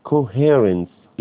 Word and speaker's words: coherence: coherence التماسك، coherence السي الاولى دي تنطق كي، coherence انعدام التماسك، يعني اتهموه بالتقليد وانعدام التماسك في الايه؟